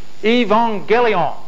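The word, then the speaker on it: Evangelion (εὐαγγέλιον): In the original language, the word for gospel is euangelion. Actually, I was told by a, a Greek person who was at the uh, meeting yesterday that you pronounce the U with a V. Evangelion is uh, my rough attempt to say it in rigidage Greek.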